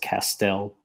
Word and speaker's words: Castellan: Yeah, but like of a castle, hence the spelling. You know, but it's still pronounced Castellan.